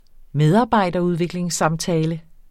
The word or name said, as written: medarbejderudviklingssamtale